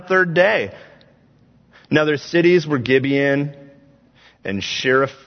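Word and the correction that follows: Chephirah: Oh boy, I had all the confidence in the world going into that one. And I just, Chris, how do we say it? Shephira. There we go.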